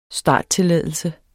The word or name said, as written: starttilladelse